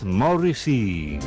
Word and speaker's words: Morrissey: musical llamado Morrissey, o como se pronuncia en inglés, Morrissey.